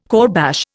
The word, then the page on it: kourbash: Indian